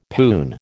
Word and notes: poon: American English

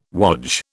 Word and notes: wodge: British English

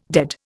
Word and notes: dead: Indian

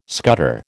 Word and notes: scutter: American English

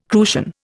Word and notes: crucian: Indian

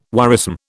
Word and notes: worrisome: British English

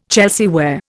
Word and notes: Chelsea ware: Indian

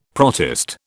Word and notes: protist: British English

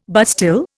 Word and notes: bastille: Indian

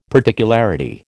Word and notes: particularity: American English

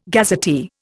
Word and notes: gazetteer: Indian

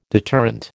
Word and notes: deterrent: American English

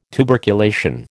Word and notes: tuberculation: American English

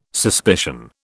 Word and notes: suspicion: British English

